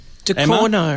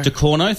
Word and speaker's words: Ducournau: Ducournau. De Corno, thank you.